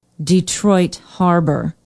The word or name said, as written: detroit harbor